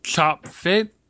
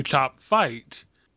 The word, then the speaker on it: Chopfyt: Well, okay, I could see it being named Chop Fit, but, you know, the name is supposed to be Chop Fight. You know, in the book it's spelled Chopfyt. Clearly Chop Fight.